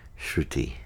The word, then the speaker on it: Shruti: it's fine if you pronounce it Shruti. Shruti. But Shruti is Shrut. In Sanskrit, it means which is heard.